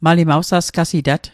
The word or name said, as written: sasqasitat